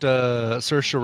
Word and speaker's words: Saoirse: It's got Saoirse Ronan. Mm-hmm. Rhymes with Inertia Ronan.